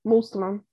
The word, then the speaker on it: Muslim: If we listen to the way our grandparents talk about people who are Muslim, No, you have to say it correctly. Muslim.